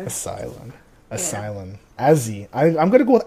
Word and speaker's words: Azee: If you're saying his name with the short form, it's Azzy. Okay? Yep. So, it's Azzy with, you know, A-Z-E-E.